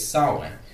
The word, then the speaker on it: Samhain: I keep trying to pronounce it phonetically, but it's Samhain.